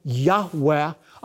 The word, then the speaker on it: Yahweh: which most Semitic scholars believe is pronounced a breathy Yahweh, a living, active form of I Am, the day that the God of Israel gave His people the Torah.